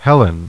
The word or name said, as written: Helen